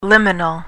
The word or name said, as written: liminal